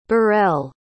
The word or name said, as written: burrell